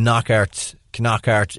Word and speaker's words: Knockaert: I was going to say, I was thinking of taking Knockaert in and bringing... – You pronounce that K. – Yeah, on the front.